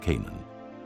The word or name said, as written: Canaan